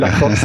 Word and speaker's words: Lacroix: De har ju fransmannen som jag aldrig kan uttala på rätt sätt, mittbacken Lecroix, Lacroix, ursäkta mitt uttal. Lacroix tror jag. Lacroix, tack så mycket.